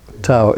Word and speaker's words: Tawe: Tau, T-A-W. It's pronounced Tau, Tau, and it's got a similar, you know, the Chinese word is T-A-W, well, it's in Chinese characters, but the English approximation is T-A-O, but they sound the same.